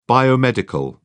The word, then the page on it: biomedical: British English